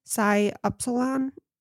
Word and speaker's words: Psi Upsilon: Psi Upsilon. I think that's right. – I always get confused because it looks like it's pronounced Upsilon, but I always hear it pronounced Epsilon. So anyways, he was liked by many, but his struggles were apparent.